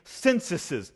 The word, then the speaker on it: censuses: You can see it through his consistent policy of taking censuses. And yes, I looked it up. That is the correct way to say that in the plural. Censuses.